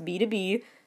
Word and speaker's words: BTOB: Then there's B2B, which for years I thought was B-T-O-B, but no, it's pronounced B2B.